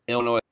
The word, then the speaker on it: Illinois: Illinois. Is that how you guys pronounce it? – Yep. – Down there? Because you're fucking new? – Yep. Illinois.